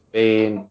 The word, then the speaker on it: Bane: It's spelled like Young, Y-O-U-N-G. – Right. – Bane, B-A-N-E, but he uses Bane. – Yeah. – I gotcha. Like in Batman, okay.